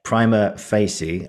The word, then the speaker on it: Prima Facie: I've said it wrong again. Prima Facie, as I've said a few times on this, sort of the main sort of body of today.